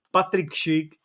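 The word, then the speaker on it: Patrik Schick: no já mám něco lepšího teda, já mám Patrika Šika, který se píše se S, protože to, i když se to čte se Š. Patrik Šik, skvělý český fotbalista, momentálně hrající za Bayern Leverkusen.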